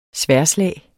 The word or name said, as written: sværdslag